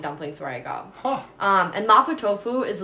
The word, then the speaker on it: mapo tofu: Mapo tofu. – Mapo tofu.